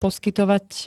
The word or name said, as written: poskytovať